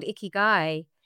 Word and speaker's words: Ikigai: I read also that you use something called Ikigai. Is that how you pronounce it? – Absolutely. Well then.